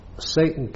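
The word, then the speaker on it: Saighton: Now, Satan is not, as it seems, Satan is an area of Chester. – It's not spelled that way either. – No, it's not spelled Satan, it's spelled S-I-A-G-H-T-O-N.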